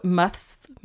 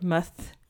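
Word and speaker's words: musth: It's muths. I can't say that word. Muth.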